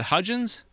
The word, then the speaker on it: Hudgens: The trio joins Vanessa Hudgens in the... is it Hudgens? – Yes.